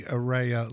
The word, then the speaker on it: Ariya: But the car that we've been looking for from Nissan is the Ariya, and I hope I'm saying that right. A-R-I-Y-A, Ariya.